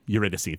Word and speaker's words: Eurydice: Eurydice. Eurydice, thank you.